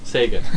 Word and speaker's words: Sega: Sega.